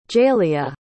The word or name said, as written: jaeleah